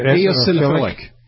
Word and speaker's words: Eosinophilic: Eosinophilic.